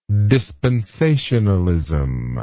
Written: dispensationalism

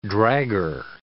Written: dragger

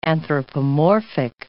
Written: anthropomorphic

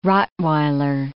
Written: rottweiler

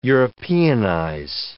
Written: Europeanize

